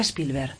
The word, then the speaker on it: Spielberg: ¿Qué significa Spielberg? No sé si lo digo bien. – Sí, sí, sí, lo dices perfectamente.